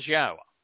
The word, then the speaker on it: Yahowah: It can be easily understood and pronounced based upon the book that it is provided to us in Towrah, and it is Yahowah. There is no question that is how it's pronounced.